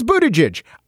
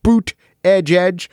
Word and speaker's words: Buttigieg: He says Buttigieg. I know phonetically that they tried to help people by making signs and t-shirts that say boot edge.